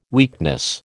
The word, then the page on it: weakness: American English